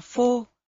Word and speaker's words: phô: Chữ pho, danh từ, không thích hợp, phải được đọc là phô, động từ, phô có nghĩa là trình bày ra.